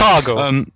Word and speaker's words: Otago: How do you say Otago? – Sounds right to me. – Yeah. I've always wanted to say it. No, that'll do. Stop it. Otago.